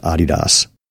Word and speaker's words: Adidas: Adidas right now is doing a thing, and here, if you want to be a footwear snob, it's pronounced Adidas.